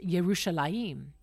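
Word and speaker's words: Yerushalayim (ירושלים): In the Hebrew language, they don't use J's. And that's why Jerusalem is actually pronounced Yerushalayim.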